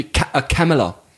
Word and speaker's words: Kamala: Fast wie Kamala. Fast wie Pamela, oder? Nicht? – Ah, stimmt, ja, genau.